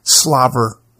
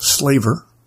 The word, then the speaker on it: slaver: Slaver, pronounced slaver or slaver, has two completely different meanings depending on whether it refers to the act of drooling or historical human trafficking.